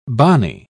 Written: bani

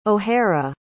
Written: O'Hara

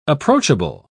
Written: approachable

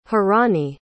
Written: hiraani

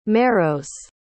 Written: merrowes

